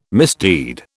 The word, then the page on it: misdeed: British English